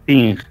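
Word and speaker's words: Ting (听): escuchar en chino se dice Ting.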